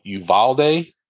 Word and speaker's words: Uvalde: And these cops in this place, let's see if I say it right. The Uvalde Police Department. Am I saying it correct? Everyone? Yes.